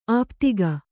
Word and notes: ಆಪಾದಕ: Kannada

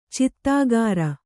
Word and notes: ಚೀತ್ಕಾರ: cītkāra

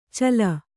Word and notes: ಚಲ: Kannada

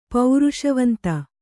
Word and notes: ಪೌರುಷವಂತ: Kannada

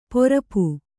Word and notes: ಪರಪು: parapu

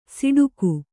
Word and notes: ಸಿಡುಕು: siḍuku

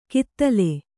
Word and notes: ಕಿತ್ತಲೆ: kiittale

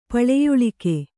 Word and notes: ಪಳೆಯುಳಿಕೆ: paḷeyuḷike

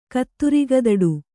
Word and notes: ಕತ್ತುರಿಗದಡು: kannada

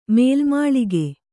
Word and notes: ಮೇಲುಮಾಳಿಗೆ: Kannada

